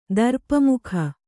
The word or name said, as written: ದರ್ಪಮುಖ